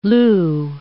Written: lieu

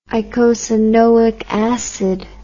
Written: icosanoic acid

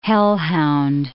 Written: hellhound

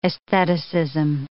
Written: aestheticism

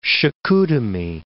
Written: Chicoutimi

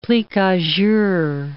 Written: plique-a-jour

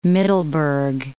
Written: Middelburg

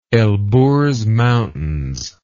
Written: Elburz Mountains

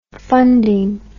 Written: funding

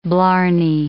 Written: blarney

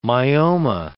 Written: myoma

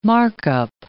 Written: markup

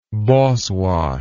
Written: Boswash

